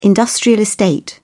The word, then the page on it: industrial park: British English